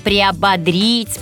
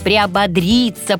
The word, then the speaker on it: приободрить: А вот глагол «бодрить», «бодрит», «бодрят» помогает запомнить ударение в «приободрить». «Бодрить» – «приободрить». Ну, а теперь приободритесь и приободренными приступайте к исполнению своих замыслов.